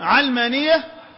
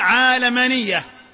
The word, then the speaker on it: علمانية: وكان من قواعد تسهيل اللغة العربية أن تأتي الكلمات على غير قياس، لما نقول اليوم علمانية نقول على غير قياس عند أهل اللغة، لأن القياس عند اللغة يفرض علينا أن ننطقها عالمانية، لكن هذه الكلمة خرجت بنا إلى مصطلح جديد، وهم سموه علمانية تسهيلا